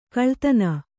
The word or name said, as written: ಕುಲ್ಲತನ